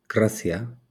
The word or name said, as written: grazia